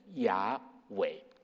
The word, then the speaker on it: 雅伟: 好，那其实这个称呼的背后有一段很长的故事，如果要照希伯来原文的发音来念的话，应该是读成雅伟。